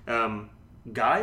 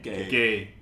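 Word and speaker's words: Gueye: Played a 3-5-2. Actually was quite effective. Up front, no Lukaku. Yeah, I know, eh? Hipster. Guy, I think is that how you say his name? – Gay.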